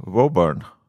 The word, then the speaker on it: Woburn: Jag sa Woburn. – Woburn. Wo typ Woburn. Som, wow! Don't burn me!